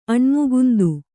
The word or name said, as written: ಅಣ್ಮುಗುಂದು